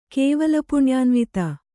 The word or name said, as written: ಕೇವಲಪುಣ್ಯಾನ್ವಿತೆ